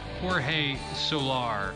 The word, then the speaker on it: Jorge: Your top American League home run hitter is it's spelled like George, but it is called Jorge Soler from the Kansas City Royals, who hit 48 home runs this year.